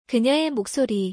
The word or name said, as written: 그녀의 목소리